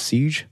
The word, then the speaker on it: Siege: I think it's pronounced Siege, S-I-E-G-E.